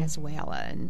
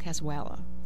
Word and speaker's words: cazuela: cazuela. I said that wrong, cazuela. Did I say it right? – Yeah, I think you did.